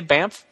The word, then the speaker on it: Banff: Didn't they say Banff? – Yeah. They said that we were pronouncing it correctly.